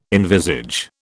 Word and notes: envisage: British English

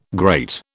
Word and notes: great: British English

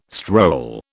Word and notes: stroll: British English

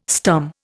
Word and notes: stum: Indian

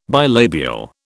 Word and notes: bilabial: British English

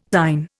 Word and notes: thine: Indian

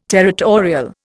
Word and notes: territorial: Indian

